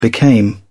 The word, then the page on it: became: British English